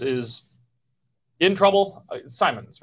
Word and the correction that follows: Simon: right? That's how you say it? – Simon. Mary Simon.